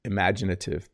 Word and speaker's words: imaginative: And we would describe a person who uses their imagination a lot as imaginative. Let me repeat that word. It's a little hard to pronounce. Imaginative, imagination, imaginative. I barely say the first vowel, don't I?